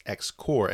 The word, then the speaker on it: X-Corp: It's interesting because this is X-Corp's Island, but the way it's spelled is actually pronounced X-Core.